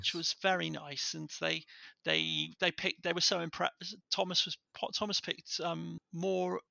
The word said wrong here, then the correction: Tomas: Is it Thomas? It's spelled Tomas. – Tomas.